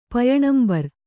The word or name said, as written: ಪಯಣಂಬರ್